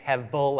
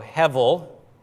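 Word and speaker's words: hevel (הֶבֶל): You see the B there? I'm saying hevel, like level, right? That's the B with a little line under it. It's soft, so hevel is how you say that.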